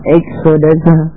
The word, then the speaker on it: Exodus: Exodus, we love Exodus, it rhymes with expert and etc.